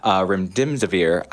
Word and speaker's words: remdesivir: is pushing called remdesivir.